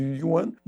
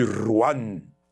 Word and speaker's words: yuan: Até tá um, fica uma diferença grande de pronúncia, né? Porque a gente chama de yuan e o portenho certamente chamará de ruan.